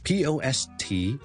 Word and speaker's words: postpone: 今日咧，聽眾問蕭叔叔咧，postpone， postpone， postpone。咁 postpone 嘅意思係延遲啦，postpone。點解有時聽落去人讀個 postpone 咧，兩個 PM 嘅發音好似有啲唔同。第一個 push，第二個係 bone 咁，好似後面嗰個聽落去有少少似一個 B 音咁。